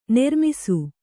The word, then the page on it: ನೆರ್ಮಿಸು: nermisu